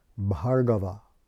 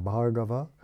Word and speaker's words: Bhargava: Uh, the word, by the way, in the Sanskrit for Parshurama is Bhargava, with a long A. That first A is long, Bhargava, because, which means the, it's, it's a patronymic.